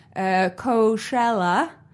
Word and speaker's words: Coachella: Coachella. That's how you say it, right? – That's how the cool kids are saying it these days.